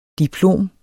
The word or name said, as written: DIPLOMS